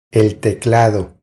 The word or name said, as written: El teclado